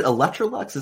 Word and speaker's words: Electrolux: Are you still at, is it Electrolux? Is that how you say it? – Yes, that's correct.